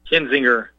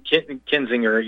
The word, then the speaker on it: Kinzinger: How do you say his name? – Kinzinger. Don't worry, everyone struggles with it. Kinzinger, yeah, or Kinzinger. People have said it different ways.